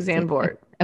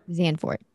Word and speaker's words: Zandvoort: I think Zandvoort. Okay. Zandvoort. Thank you. No, the D I think is silent. – Zandvoort. – Zen. Yeah, like that.